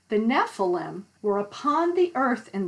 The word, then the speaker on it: Nephilim: And interestingly, this word Nephilim, and I'm going to spell it so you know where in the Bible it says this word. It's N-E-P-H-I-L-I-M, Nephilim.